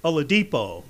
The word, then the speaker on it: Oladipo: Victor Oladipo. – Oladipo. There we go. Oladipo.